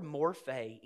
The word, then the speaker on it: morphe: We would spell that M-O-R-P-H-E, morphe.